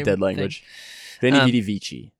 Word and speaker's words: Veni, vidi, vici: Veni, vidi, vici. – Okay, you said Italian. – But I think I don't know if the V is pronounced with the W.